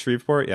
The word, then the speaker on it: Shreveport: Am I pronouncing that right? Shreveport? Yes.